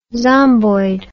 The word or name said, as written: zomboid